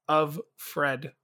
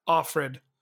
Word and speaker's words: Offred: And now her maiden name or her maid name is handmaiden name is of Fred or Offred, because they say it like all proper. So it's Offred. So off David.